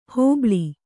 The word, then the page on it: ಹೋಬಳಿ: hōbaḷi